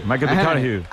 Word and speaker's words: McConaughey: Hay que ir corrigiendo la pronunciación. – McConaughey. Bueno, pues el Matthew se...